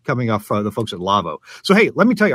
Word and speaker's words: LAWO: Now, it's pronounced Lavvo, but it's spelled L-A-W-O because it's a German company.